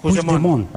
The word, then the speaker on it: Puigdemont: Puigdemont. – Puigdemont. – Vale. – Puigdemont. – Eso es. – Sí, lo dices como francés, así. Tiene Puigdemont, como parece como una isla.